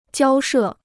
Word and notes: 交涉: jiāo shè

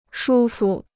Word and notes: 舒服: shū fu

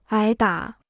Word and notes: 挨打: ái dǎ

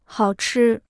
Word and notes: 好吃: hào chī

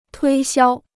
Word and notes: 推销: tuī xiāo

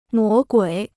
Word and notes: 魔鬼: mó guǐ

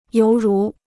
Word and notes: 犹如: yóu rú

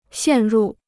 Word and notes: 陷入: xiàn rù

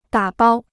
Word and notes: 打包: dǎ bāo

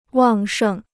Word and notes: 旺盛: wàng shèng

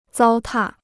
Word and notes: 糟蹋: zāo tà

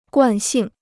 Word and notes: 惯性: guàn xìng

